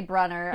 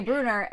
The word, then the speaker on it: Brunner: And then in the show, they say Bruner. And people are like, I don't know what to think because obviously they're going to pronounce it correctly because Rick is involved and yeah. he knows how these characters' names should be pronounced.